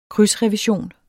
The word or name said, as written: krydsrevision